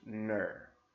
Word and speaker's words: gnurr: The lint that is found there is called Nurr. It's spelled G-N-U-R-R, and yeah, apparently somebody thought it was a wise decision to give that a name.